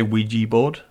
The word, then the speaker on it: Ouija: Would you say Ouija board? Is that how you pronounce it? – Ouija board? – I'd say Ouija board. – Yeah. – Well, it's spelled O-U-I-J-A. – Oh. – Yeah. – What? – O-U-I-J-A. That's how you spell Ouija. – All right. – So, Ouija is how it is spelled phonetically. – Ouija. – Yeah, or it should at least be Ouija board, I think.